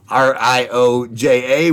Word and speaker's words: Rioja: It's uh, it's a Riaca R I O J A, which I've been told is pronounced Rioja.